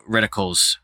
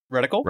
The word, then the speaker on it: reticle: I wish there had been like extra reticules reticles uh, or, or retic- I don't know how to pronounce it. Reticle. – Reticle.